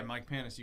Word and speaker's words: Mike Panus: Yeah, Mike Panus, you got it, brother.